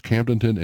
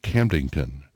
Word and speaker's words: Camdenton: Good morning, Joe, Bobby Lee, Jerry, also Rick, who says there's two ways to pronounce Camdenton. There's Camdenton and Camdington.